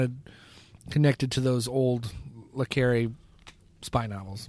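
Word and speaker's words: le Carré: Specifically, when you say spy novels, it really goes to John le Carre, John le Carre, however you want to pronounce it. – Yeah, something like that. – I believe it's pronounced le Carre, but anyway, he wrote Tinker Tailor, Soldier Spy.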